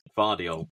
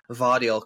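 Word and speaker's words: Gvardiol: Um, my three defenders are Gavardile. I can never say his name, even though I practiced before what? doing Vardiol. Vardiol, because you don't pronounce the G, there.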